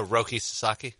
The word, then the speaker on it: Roki Sasaki: Roki? – Oh, Roki Sasaki.